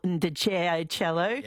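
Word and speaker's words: Ndegeocello: I'm going to say this wrong, but Mark and Ashley has pointed out Michelle Ndjeajcello. – Is that how you do it? Yep.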